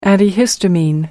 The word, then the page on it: antihistamine: American English